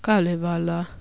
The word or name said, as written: kalevala